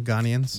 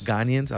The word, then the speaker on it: Ghanaians: Ghanaians. – Ghanaians.